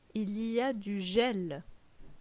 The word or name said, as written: Il y a du gel